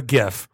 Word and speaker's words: GIF: thank you, by the way, Jif Peanut Butter, that finally came out of the woodworks and said, we're Jif, they're Gif. Hard G, J.